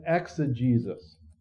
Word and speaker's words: exegesis: It's called eisegesis and exegesis. That's how you pronounce those.